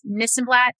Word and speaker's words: Nissenblatt: Nissenblatt. yeah. If you're ever confused about how to pronounce my last name, it's Nissenblatt. Sounds like this and that.